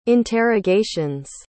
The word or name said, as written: interrogations